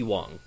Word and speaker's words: Wong: How do you pronounce that in... – Bud Wong. – Yeah, that's how you actually pronounce it.